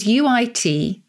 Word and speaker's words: uitweien: And it's from the Dutch, and excuse the pronunciation, uitweien, which is uitweien. Uitweien. – Spell it again.